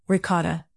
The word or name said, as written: ricotta